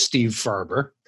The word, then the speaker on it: Steve Farber: Well, I believe it's pronounced Steve Farber. I've been pronouncing it that way for 61 years.